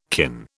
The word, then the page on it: kin: British English